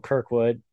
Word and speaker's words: Kirkwood: I mean, you had late in the race, you had David Malukas and Kyle Kirkwood. Am I saying that right? Yeah. Yes.